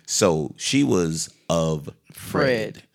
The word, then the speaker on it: Offred: but her name is spelled O-F-F-R-E-D, Offred. And they kept saying Offred.